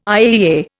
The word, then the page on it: IAEA: Indian